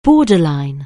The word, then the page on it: borderline: anglais